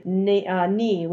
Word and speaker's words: nae: That becomes a contraction, with the not being replaced by knee, which is spelled N-A-E.